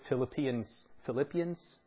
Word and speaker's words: Philippians: Philippians is how you say it.